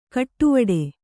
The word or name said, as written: ಕಡೆವೀಡು